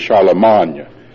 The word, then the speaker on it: Charlemagne: I remember when I was taking French, the way that you should correctly pronounce it is Charlemagne, like champagne. We say champagne. Champagne is the French, and Charlemagne is the correct pronunciation for Charlemagne.